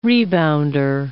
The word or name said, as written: rebounder